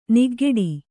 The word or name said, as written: ನಗೆಗೇಡಿ